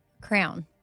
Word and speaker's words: crayon: Crayon. – Excuse me? – A crown. – I'll accept it. You said crown, but it's crayon.